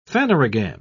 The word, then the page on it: phanerogam: British English